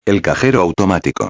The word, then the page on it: el cajero automatico: espanhol